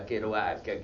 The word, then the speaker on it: Kerouac: Possiamo dire che da Kerouac, che si pronuncia così perché teneva molto Jack alle sue origini canadesi, franco-canadesi, ancora più incrociate con una tribù indiana di nativi americani.